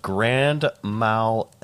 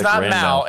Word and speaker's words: grand mal: Nope, I'm going to say grand mal. It's not mal, it's mal.